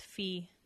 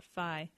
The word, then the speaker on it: phi: Yes, this is a super cool website, obviously related all about phi, and which I actually read something today, it's pronounced fee, not phi.